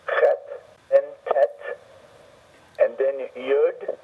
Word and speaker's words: Yud: So some people write it as a Y-O-D, other people write it as a Y-U-D, but the idea is the Y. – Okay. – Now, doesn't matter whether it's U-D or O-D. It's to get the person to articulate the Y sound.